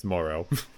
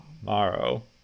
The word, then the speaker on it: Morrow: I think it's Morrow. – Morrow.